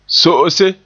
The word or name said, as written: so'ose